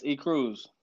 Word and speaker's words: ECruse: It's ECruse.